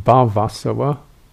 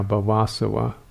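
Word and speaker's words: bhavāsava: And then the other one is the arisen taint of being or becoming, bhavasava, B-H-A-V-A, long A, B-H-A-V, long A, S-A-V-A, bhavasava, which is the taint or the outflow of becoming or being.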